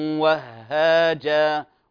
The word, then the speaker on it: وهاجا: سراجا وهاجا، لاحظوا الهاء حرف مضعف يعني مشدد، لا يغن لا يغن، لكن انتبه لا تقول وهاجا وهاجا، هو عبارة عن هاء ساكنة وهاء متحركة، فانتبه أعطيه المقدار الزمني المناسب. سراجا وهاجا وهاجا، فتنطق هاء ساكنة وهاء متحركة، بارك الله فيكم.